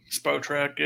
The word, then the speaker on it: Spotrac: How do you say that? Spotrac? Spotrac? – Spotrac, yeah.